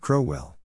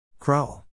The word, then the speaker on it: Crowell: Period. Not Crowell. Crowell. Rhymes with owl.